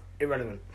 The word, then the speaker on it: IRRLVNT: Yeah, we are Irrelevant, spelled I-R-R-L-V-N-T, pronounced Irrelevant.